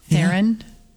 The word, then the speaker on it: Theron: You say Theron? Is that Theron. How you say it? Theron? – Yeah. No, it's Theron.